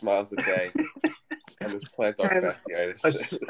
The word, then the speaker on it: plantar fasciitis: Plantar fasciitis.